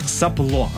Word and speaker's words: сопло: Далее, слово, которое, конечно, мы каждый день используем в своей жизни, да, сопло, ну, часто, наверное, так и произносим, сопло, правильное ударение.